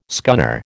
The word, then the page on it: scunner: American English